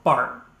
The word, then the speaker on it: Barth: So this week's idea comes from a man named Karl Barth. And his name is kind of spelled B-A-R-T-H, his last name. So it looks like Barth, but it's Bart. Don't say it wrong.